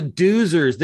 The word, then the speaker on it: Doozers: I think in the last episode in that we covered this back in episode 14, you referred to them as doozers? Dozers? How do you say it? Oh, yeah, the doozers. They're totally the doozers from Fraggle Rock. They are totally the doozers.